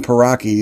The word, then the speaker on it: Pauraque: The common Paraki is spelled Paraki, P-A-U-R-A-Q-U-E.